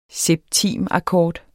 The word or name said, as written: septimakkord